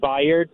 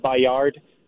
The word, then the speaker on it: Byard: but his name is pronounced Kevin Byard, not Bayard.